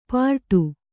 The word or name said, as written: ಪರಡು